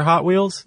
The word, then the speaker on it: Hot Wheels: And apparently, if you go look at the old original commercials for Hot Wheels. – Did they say that? – That's how they pronounce it. Hot Wheels. – Oh, instead of Hot Wheels? – Yeah. The emphasis is on the hot.